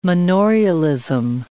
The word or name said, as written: manorialism